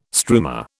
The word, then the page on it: struma: British English